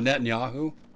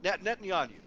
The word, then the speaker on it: Netanyahu: Oh, Netanyahu. – Netanyahu.